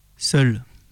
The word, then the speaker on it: Séoul: Seul. C'est ainsi que s'entend Séoul, prononcé en hangul. C'est probablement la meilleure façon d'énoncer le nom de la capitale du pays pour être compris d'une oreille coréenne.